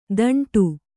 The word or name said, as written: ತಾಂಟು